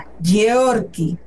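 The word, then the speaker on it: Georky: ¿Y cómo se escribe Yorky Lara? Pues es como si escribieras George con G. G-E-O-R-K-Y. Yorky. Otra vez, así como Geo, como Georgina, pero en vez de Georgina, Geo.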